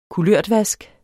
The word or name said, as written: kulørtvask